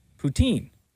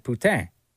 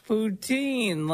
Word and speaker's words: poutine: And I always pronounce it poutine. – Right. – Which I guess is wrong. And this person called in to say the way you're pronouncing it is wrong for this particular reason. – The way you said poutine was actually poutin, which means whore. You straight up just ordered a whore with your burger.